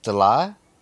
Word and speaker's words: Delyle: And he laughed at the attempt, our attempt to say his last name, because it's pronounced D-E-L-Y-L-E. So, DeLay? Some crazy French name with a silent S.